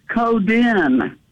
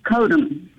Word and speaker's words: Coden: He pronounced it Coden, not Codem.